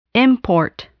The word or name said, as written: import